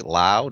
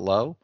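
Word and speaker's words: Lowe: I think his name is pronounced right. Lau, not low.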